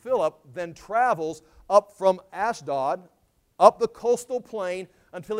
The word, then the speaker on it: Ashdod: Ashdod, it's pronounced here Ashdod, but it's the ancient city of Ashdod.